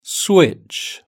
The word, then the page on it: switch: British English